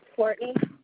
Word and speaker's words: Courtney: Courtney, C-O-U-R-T-N-E-Y. – And can I have that spelling of your last name? – S-N-E-A-D.